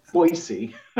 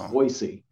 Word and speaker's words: Boise: Boise. Boise was how I used to say it. Oh. And it's Boise, apparently.